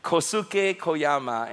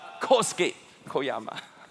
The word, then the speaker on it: Kosuke Koyama: Many years ago, a Japanese theologian, Kosuke Koyama, I got to say it right, I would say Kosuke Koyama, and then Naomi, our wonderful Japanese staff, say, Pastor, that's wrong, it's Kosuke Koyama.